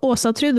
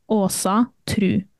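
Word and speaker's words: Ásatrú: So Osotru, the way I pronounce it, is with the Scandinavian O, the A with the circle on top, Osotru with a U.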